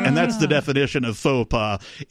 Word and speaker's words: faux pas: F-A-W. – F-A-W. – P-A. – PA. And so it was pronounced FAPA repeatedly, but it should have been pronounced like the significantly embarrassing blunder of mistake that yet another useless government alphabet agency actually is, which is faux pas.